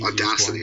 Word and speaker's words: Audacity: Yep, because I find I've tried a bunch of programs, but Audacity is the best and easiest Audacity, one. Okay, that's how you say it.